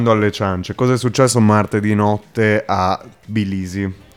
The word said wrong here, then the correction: Tbilisi: Che, perdonerete la pronuncia, Bilisi. – Tbilisi. Tbilisi, va bene.